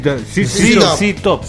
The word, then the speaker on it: ZZ Top: ZZ Top.